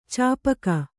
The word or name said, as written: ಚಾಪಕ